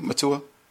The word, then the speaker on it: Mutua: Am I saying it correctly, Mutua? – Mutua, yes, exactly.